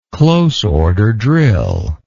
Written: close order drill

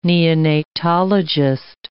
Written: neonatologist

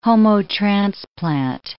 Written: homotransplant

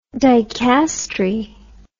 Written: dicastery

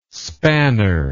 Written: spanner

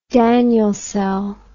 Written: Daniell cell

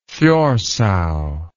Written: thjorsa

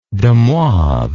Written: demoivre

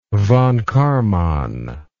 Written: von Karman

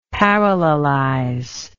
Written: parallelize

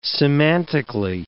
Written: semantically

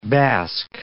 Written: Basque

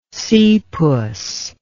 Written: seapuss

